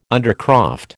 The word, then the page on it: undercroft: American English